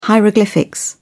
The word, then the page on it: hieroglyphics: British English